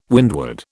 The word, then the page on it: windward: British English